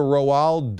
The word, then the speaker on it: Roald: His name is R-O-A-L-D. It's like Ronald without an N. You pronounce that with one syllable?